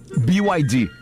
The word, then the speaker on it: BYD: BYD. – Que não pude deixar passar essa oportunidade. Faz questão de explicar que o correto é pronunciar... – BYD. – Falando o nome das letras em inglês.